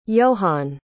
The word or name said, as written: johan